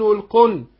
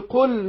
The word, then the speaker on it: قل: قل، القاف حرف مستعلي، حرف مفخم، أو القل، واللام، اللام هنا أحرص على إظهارها، أو القل، لأن بعض الناس ممكن ينطقها نون والقن، قل يا أيها، لا قل، يبقى اللام تظهر وأحرص على إظهارها.